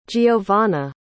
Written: giovanna

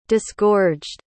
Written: disgorged

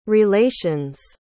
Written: relations

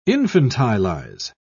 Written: infantilize

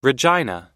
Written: regina

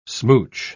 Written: smooch

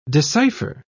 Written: decipher

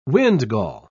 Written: windgall

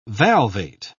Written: valvate

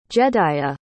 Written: jedaiah

